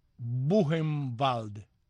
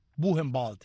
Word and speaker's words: Buchenwald: Pero Buchenwald, el campo de concentración que está cerca de Leipzig, se pronuncia Buchenwald. Buchenwald.